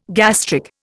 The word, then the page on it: gastric: Indian